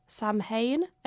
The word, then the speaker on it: Samhain: Some believe it originates from the Celtic pagan festival of, I don't know how to pronounce this, Samhain, S-A-M-H-A-I-N.